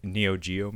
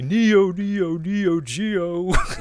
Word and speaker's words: Neo Geo: SNK made the Neo Geo machine, or I should say it correctly, Neo Geo.